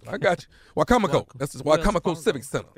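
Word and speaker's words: Wicomico: And then Saturday, October the 5th, Wacomico Civic Center. That's Salisbury, Maryland. Am I saying it right? Salisbury. Salisbury, Maryland. – Was you trying to say Wachovia? – No, it's Wacomico.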